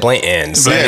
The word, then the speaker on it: Blanton's: Yeah, by the way, and you say it right. You said Blanton's.